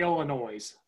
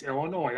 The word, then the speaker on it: Illinois: People say Illinois. It's Illinois. I guess it's silent.